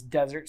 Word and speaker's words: Desert: My last name is Desert, spelled like dessert.